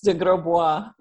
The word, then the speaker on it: de Grosbois: It's de Grosbois. It's pronounced the French way.